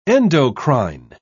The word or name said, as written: endocrine